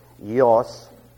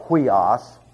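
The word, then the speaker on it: yos (υἱός): It is not oi. As you will hear people say, and that is why they mess up such beautiful words as yos, and they go huios for sun, and all kinds of crazy things like that.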